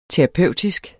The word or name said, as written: terapeutisk